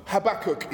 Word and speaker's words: Habakkuk: Habakkuk is how I pronounce it. Some people pronounce it Habakkuk and don't manage to. There's three K's in this, so it's like there's lots of hard K's, but Habakkuk is the book of a minor prophet in the Old Testament.